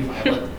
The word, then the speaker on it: Vilate: Kimball, who eventually will become Brigham Young's first counselor in the first presidency, and his wife, Vilate, V-I-L-A-T-E. It might be Violet, but everybody I've heard says pronounce it Vilate.